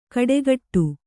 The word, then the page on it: ಕಡೆಗಟ್ಟು: kaḍegaṭṭu